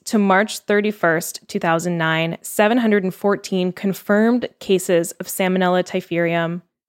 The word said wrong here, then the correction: Typhimurium: Oh, I think I'm saying that wrong. Typhimurium.